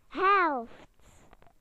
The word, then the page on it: half: British English